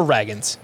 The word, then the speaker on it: Ragans: The Rangers, they have a pitching prospect named Cole Reagans, or Ragans. It's spelled R A G A N S.